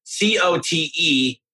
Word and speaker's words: Cote: It's spelled C O T E, but it's Cote.